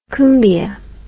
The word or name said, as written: cumbia